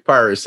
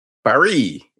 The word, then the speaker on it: Paris: Paris. – Paris. – No, Paris. You got to roll that tongue, buddy.